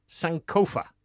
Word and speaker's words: Sankofa: Today we have, is it pronounced Sankofa? – That's the one.